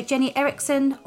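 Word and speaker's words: Jennie Eriksen: So Jenny Erickson, or under the name The Nifty Networker.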